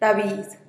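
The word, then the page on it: rabiiz: Teotitlán del Valle Zapotec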